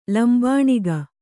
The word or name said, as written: ಲಂಬಣಿಗೆ